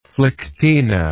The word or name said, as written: phlyctena